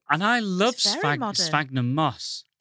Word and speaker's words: sphagnum: And then there's an artist's impression of the design for the new Ilkley Fountain, which is based on, and I don't know how to pronounce this, sphagnum moss. – Yeah, that is sphagnum.